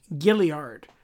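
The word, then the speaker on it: Gaillard: Gilliard. There is no Y. There is no I in the right place to make you do a gilly sound. It's spelled like Gail Lard. – Okay. – And they call it Gilliard.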